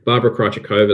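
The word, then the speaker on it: Barbora Krejčíková: And the other name, and I'm impressed that you've got the pronunciation right after the last couple of slams, Barbara Krejcikova is one to watch as well.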